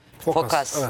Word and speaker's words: Phokas: Milattan sonra 1000 civarında zamanın Bizans imparatoru Phokas mı diye okunuyor? – Phokas. Evet.